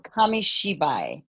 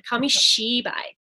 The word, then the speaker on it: Kamishibai: It's a Japanese word, so it's difficult, but it's called Kamishibai. – Yes, thank you. I knew we would need a pronunciation help on that one. Kamishibai.